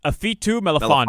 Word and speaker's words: Ifeatu Melifonwu: How do you pronounce this guy's name? Ifeatu Melifonwu? – Melifonwu. Mm-mm. And it's Ifeatu Melifonwu.